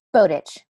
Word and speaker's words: Botic: Botic. – Botic. Oh, I never would have guessed that. – No, it's not at all how it looks.